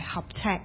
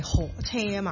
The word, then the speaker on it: 合尺: 我覺得首先咧，我哋唔入個音樂住咧，都要學咗個讀音先，因為頭先你都記得啦，唔係合尺係河車啊嘛。